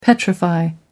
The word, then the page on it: petrify: American English